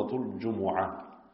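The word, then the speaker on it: Jumu'ah: So this is the 62nd surah of the Quran, and it is Surah Al-Jum'ah or Jum'ah. Both are correct pronunciations.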